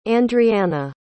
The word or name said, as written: Andrianna